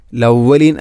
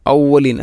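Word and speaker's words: ഔവലീൻ (الأولين): അസാതീറുൽ അവലീൻ, ലവലീൻ അല്ല ഔവലീൻ, ഹംസയെ കൊണ്ടാണ് പറയേണ്ടത്. അസാതീറുൽ അവലീൻ.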